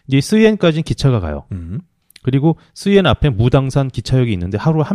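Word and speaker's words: 쓰옌 (十堰): 얘네 발음은 쓰옌이라고, 쓰옌. 쓰옌시는 호북선에도 굉장히 유명한 큰 도시입니다.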